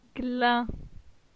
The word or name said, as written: Glin